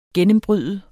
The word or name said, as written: gennembryde